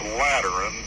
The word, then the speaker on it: Lateran: By terms of this Lateran treaty, did I pronounce that right? You did absolutely correctly so far.